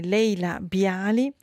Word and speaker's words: Leila Bialy: Noi continuiamo in musica, lo facciamo con Laila Bailey. Con la sua But Not For Me. Allora, correggo la pronuncia, è Leila Bialy, con questa sua But Not For Me, cantante canadese Leila Bialy, con questo brano tratto dal suo album Your Requests del 2023.